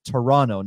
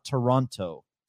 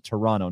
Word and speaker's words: Toronto: It's Toronto, not Toronto. Even though it's spelled with the T, you pronounce it Toronto, not Toronto.